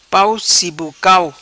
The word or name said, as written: Pau Sibocau